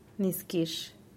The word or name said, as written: niskix